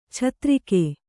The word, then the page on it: ಛತ್ತರಿಗೆ: chattarige